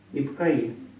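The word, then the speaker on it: Ипакои: Ипокой глаз четвертый. – Да, только Ипкаи ударение. Ипкаи.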